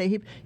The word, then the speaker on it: AHIP: El AHIP, se escribe A-H-I-P, que es el AHIP.